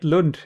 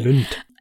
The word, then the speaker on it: Lund: I hope I pronounced that right. – Yeah, that's next door to me. So. But that's right, Lund, yeah. – Okay. – Lund.